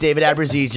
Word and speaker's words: David Abruzzese: And I got to hear from David Abruzzese. That's how you pronounce it? – Yeah. – It took me 25 years, but it's David Abruzzese. You're correct.